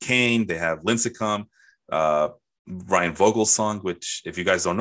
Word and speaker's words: Vogelsong: If you're German, it does. It's Vogelsong.